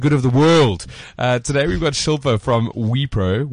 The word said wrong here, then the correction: Wipro: Wipro. Is it Wipro? – Wipro.